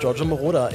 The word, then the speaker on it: Giorgio Moroder: Giorgio, ich glaube, das ist alles richtig. Giorgio Moroder.